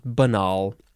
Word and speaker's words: banal: And the synonym is banal, but it looks like banal, but I think it's pronounced banal.